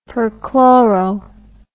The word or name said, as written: perchloro